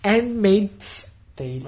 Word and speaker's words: Handmaid's Tale: e Handmaid's Tale, ho messo l'S giusta, e l'abbiamo consigliata appunto ai tempi.